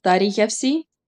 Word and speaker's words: Taríchefsi: Okay, well, the word embalming is Taríchefsi.